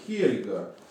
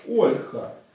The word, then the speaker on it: Ольга: Также имеется сведения, что имя Ольга – это имя скандинавское и произносится как Хельга. А у чехов как Ольха. Ольха.